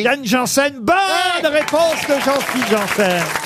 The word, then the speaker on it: Janssen: Alors c'est vrai qu'on trouve euh, Yann, est-ce que je prononce bien d'ailleurs son nom Yann Janssen, c'est bien ça Yann Janssen. – Janssen, ah c'est pas Janssen comme Jean-Fille, vous voyez.